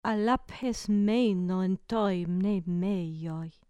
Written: aphes meino en to mneimeio

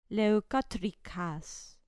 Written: leukotricas

